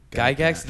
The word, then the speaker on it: Gygax: Gary Gygax. Did I say it right? – Yeah, Gary Gygax.